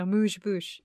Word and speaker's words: amuse-bouche: So it's really just this is like an amuse-bouche. Is that how you say it? – Yeah, it is.